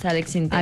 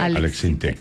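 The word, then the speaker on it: Alex Syntek: Y uno era de Alex Sinté, que usted dijo que se pronunciaba así. Yo sí. – Que... – sí, es Alex Sinté. – Alex Sinté.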